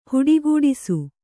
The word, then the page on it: ಹುಟ್ಟುಗೆಡಿಸು: kannada